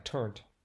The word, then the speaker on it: turnt: Or is it turntee? I don't know. I don't know either. Pronounced. – Looks like turnt.